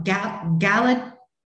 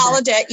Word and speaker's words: Gallaudet: and received her master's degree in linguistics from Gallaudet University in Washington, D.C. I know I completely mispronounced it. – Yeah, Gallaudet University. – Gallaudet.